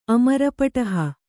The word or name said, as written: ಅಮರಪಟಹ